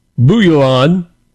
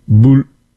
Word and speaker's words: Bouillon: Geoffrey of Bouillon. I didn't say that right. Bouillon? Bouillon elected the first defender, a ruler of the Kingdom of Jerusalem after the capture of the city.